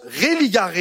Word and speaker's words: religare: Le mot religion, c'est un mot latin qui veut dire, qui se prononce religare et qui veut dire relier, attacher, relation.